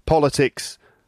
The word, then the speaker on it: politics: Politics, politician, political, politically. You can see that the word stress does change, right? Politics is the first syllable, has the stress. Politics, right, that's the world of politics.